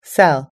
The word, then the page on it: sell: American English